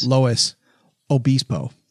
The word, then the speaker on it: Luis Obispo: Well, no, that's the way it is pronounced. San Luis. Luis Obispo. It's Obispo. – Like, it's Obispo.